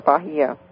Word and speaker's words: Bahia: Yes, but is it Bahia or Bahia? – Bahia. – Mm. – It's spelled Bahia.